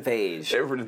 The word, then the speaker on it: Feige: It's probably Kevin Feige.